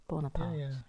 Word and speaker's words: Bonaparte: When you read about Bonaparte. Did I say that right? Because we call it Napoleon Bonaparte. – Bonaparte.